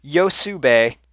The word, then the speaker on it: Hyosube: It's pronounced Yosube, and it's spelled Hyosube.